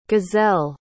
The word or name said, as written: gizelle